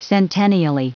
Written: centennially

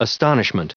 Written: astonishment

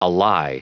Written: ally

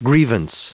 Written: grievance

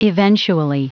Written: eventually

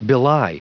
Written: belie

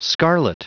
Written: scarlet

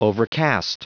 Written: overcast

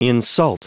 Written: insult

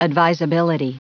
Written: advisability

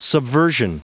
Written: subversion

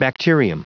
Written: bacterium

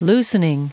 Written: loosening

